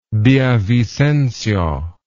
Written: villavicencio

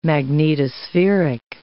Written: magnetospheric

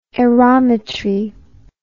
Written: aerometry

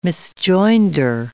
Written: misjoinder

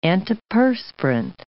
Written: antiperspirant

